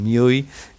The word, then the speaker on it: MIUI: que me escucha siempre y me carga con Miui, eh, que lo pronuncio mal, es M-I-U-I, que es eh, la interfaz gráfica que tiene Xiaomi para sus smartphones.